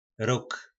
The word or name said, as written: Rog